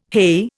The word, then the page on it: heigh: Indian